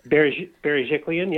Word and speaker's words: Berejiklian: The Premier, uh, excuse my pronunciation, Gladys Berejiklian. – Berejiklian, yeah.